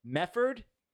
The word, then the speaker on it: Medford: And that's, I found that interesting because Mefford is how people from Medford pronounce Medford. Mefford. Hey, where are you from? I'm from Meffa.